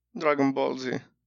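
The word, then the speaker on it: Dragon Ball Z: هي تنطق دراغون بولز ولا دراغون بول زي؟ – دراغون بول زي.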